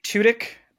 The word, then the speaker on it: Tudyk: Tudyk. – I always say Tudyk, but you have to say Tudyk. It fast. Cause otherwise it sounds like two dick and that's inappropriate. So you have to say it really quick.